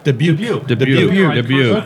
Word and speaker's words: Dubuque: And then how do you pronounce it, is it Dubuque or Dubuque? – Dubuque. – Okay, all right.